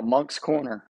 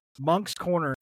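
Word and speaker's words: Moncks Corner: How do I pronounce this? – Yeah, Monk's Corner. – Monk's Corner, and it's spelled M-O-N-C-K-S. – That's it. Yep.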